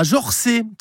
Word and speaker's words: Jorxey: Ça s'écrit J-O-R-X-E-Y. Attention sur le GPS. Hein. Si vous avez une ville vosgienne qui commence par un X, ça se prononce K2S. Xon rue Longemer. Si le X est au milieu, c'est 2S.